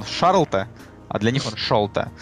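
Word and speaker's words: Шарлто: Я, я его всегда называл Шарлотта Колли, только сейчас увидел, что это П, а не Л. – А можно я вас, господа, исправлю? Его имя произносится как Шолта.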